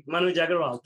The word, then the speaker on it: Manuj Aggarwal: I want you to say your name again because Manuj, with the Southern accent. – Yeah, you got it perfectly. Manuj Aggarwal.